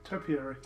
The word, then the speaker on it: topiary: And Balthasar was terrified by topiary. Why have I forgotten how to say the word? – Topiary. – It is topiary, yes. But I was reading it in my head going, it could be either.